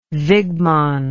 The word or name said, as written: Wigman